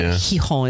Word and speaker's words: Gijón: Yeah, it's spelled Gijón, but there's different ways to pronounce it, but Gijón is my favorite in the upper, like, northern coast of Spain.